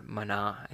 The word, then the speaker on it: manna: And you'll see when they're gathering manna, or mana, I think is actually how you say it in Hebrew, later, they want to work double.